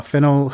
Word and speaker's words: Fennell: Oh yeah, sorry, promising young woman uh, director Emerald uh, Fen- how you say Fennell.